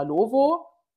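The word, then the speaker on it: Lovoo: Viele sagen, es wird Lavu ausgesprochen. Ich sage immer Lovo.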